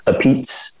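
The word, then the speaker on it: apizza: And it's pronounced a pizza.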